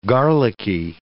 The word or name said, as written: garlicky